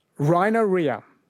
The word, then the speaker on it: rhinorrhea: Rhinorrhea.